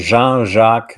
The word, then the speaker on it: Jean-Jacques: So his full name is Jean-Jacques Joseph Brun, and I go by JJ. When I was working overseas, they couldn't pronounce my name, uh, so they then became uh, John Jack. Hey, John Jack, and uh, they would i don't think they were bilingual.